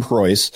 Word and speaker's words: Preuss: On iTunes, Stitcher, I mean, anywhere that you normally download a podcast, you can find the Tim Preuss podcast. You can follow me on all the social media. If you just type Tim Preuss, last name is spelled P-R-E-U-S-S, just type me into Google, and you'll find links to all of my stuff.